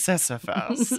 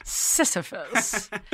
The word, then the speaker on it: Sisyphus: So the myth that you did not, that you got close to pronouncing was Sisyphus. – Sisyphus.